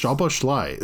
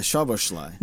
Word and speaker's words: Szoboszlai: It's really not that hard of a name to pronounce. Szoboszlai. Like it's, you just treat the Z in that name like an H. So it's like SH, Szoboszlai.